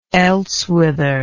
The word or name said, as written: elsewhither